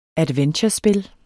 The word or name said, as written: adventurespil